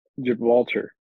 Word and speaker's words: Gibraltar: Gibraltar.